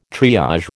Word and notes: triage: American English

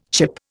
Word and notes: chip: Indian

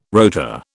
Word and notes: rota: British English

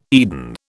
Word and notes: Eden: British English

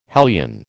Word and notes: hellion: American English

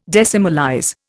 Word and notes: decimalize: Indian

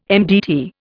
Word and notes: MDT: Indian

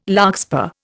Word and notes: larkspur: Indian

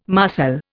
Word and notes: marcel: Indian

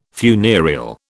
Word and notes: funereal: British English

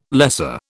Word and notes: lesser: British English

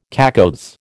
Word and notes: cacoethes: American English

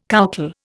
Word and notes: coucal: Indian

